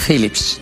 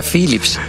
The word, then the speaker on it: Philips: se pronuncia Philips? ¿Qué cateto eres? – Ahí viene. – Porque la PH es F.